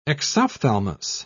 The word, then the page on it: exophthalmus: British English